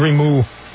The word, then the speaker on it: Grimus: I want to tell you, by the way, grimace here is spelled G-R-I-M-U-S. Grimoose. According to Grimou. That's like Camus. According to the according to the French philosopher, Grimou.